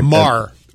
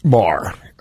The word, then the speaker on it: Maher: Yeah, Maher. – Maher.